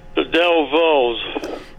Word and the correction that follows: Dovells: Not saying it right. – That's all right. You know it's the Dovells, and I'll give it to you for that.